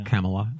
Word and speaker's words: Kamala: Kamala, that's how she pronounces it.